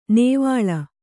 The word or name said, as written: ನೇವಳ